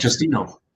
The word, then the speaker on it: Justino: Justino. – Justino? – Yep. – Oh, okay. I've been saying Justin. – Oh, Justin or Justino, either or.